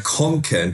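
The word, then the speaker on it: Kånken: Wie spricht man den aus, bitte? Äh, Konken. Da ist so ein A, da ist so ein, äh, Konken. – So ein runder Kreis über dem A, das heißt O.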